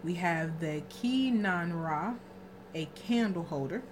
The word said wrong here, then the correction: kinara: Well, I just pronounce it Kinara. They really strong pronunciations. Kinara. It's Kinara.